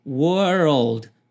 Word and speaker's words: world: Pega a primeira pronúncia, were, e a segunda, old. Depois junte, world.